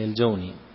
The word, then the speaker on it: الجوني: ثم الآن تنطقونها الجوني ولا لا؟ ولا اللغة الجوني؟ – الجوني نعم، هي الجوني، لكن الآن القبيلة ينطقونها بالضم، لكن الفصحى الجوني.